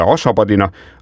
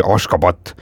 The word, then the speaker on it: Ashgabat: paljud meist võib-olla nagu lihtsustatud korras tunneksid Ashgabadina , aga tegelikult on ta nii kirjapildi mõttes kui ka häälduse mõttes ikkagi Ashgabat ja pealinnas elab noh , veits üle miljoni inimese ja linn asutati 1881.